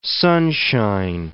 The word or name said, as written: sunshine